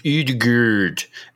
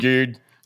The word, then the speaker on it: Odegaard: It's pronounced Odegaard. – Um, Udegaard.